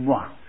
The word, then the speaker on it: moi: he was giving it the proper French pronunciation. Moi, see? – Money anyway, well spent, Tim.